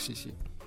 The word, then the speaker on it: Сиси: Как Сиси? – Ну, стесни. Ударение я правильно поставил.